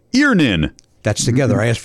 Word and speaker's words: Earnin: Earnin. Earnin. E A R N I N is pronounced Earnin.